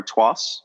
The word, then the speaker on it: Artois: Found it at local grocery markets in the frozen section by a very well-known brand, Stella Artois, or Tois, as some people like to pronounce.